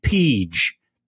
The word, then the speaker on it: Peej: And one of the horses we were covering that we were going to talk about was a horse named Peege, P-E-E-J. And so Jerry leans over to me with his past performances and points to the horse and says, "How do you pronounce this horse's name?" And I said, "The J is silent."